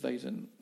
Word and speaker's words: Weizen: Et euh, comme elle est non filtrée, elle porte le nom de Weiser euh, Weizen, tu le prononces mieux que moi.